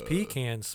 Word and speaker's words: pecans: You're like, you know what, I gotta say pecans because that motherfucker, I know he doesn't know how to say it. It's pecans.